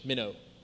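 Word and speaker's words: meno: It's M-E-N-O, but you say it like the little fish, minnow, okay?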